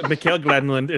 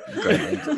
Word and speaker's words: Granlund: Following them up, I have Tolvanen, Johansson, and Granlund, or however you say his name. I know you have a special way for it. – It's Mikael Granlund. It's okay. Granlund, yeah.